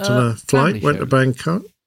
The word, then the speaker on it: Bangkok: So, could you pronounce that differently, please? – I don't think you can. I think you have to say Bangkok if you're going to Bangkok.